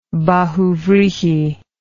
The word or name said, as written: bahuvrihi